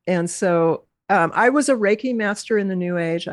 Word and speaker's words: Reiki: That Reiki, is that how you say it? Reiki? – Yeah. Reiki, yep.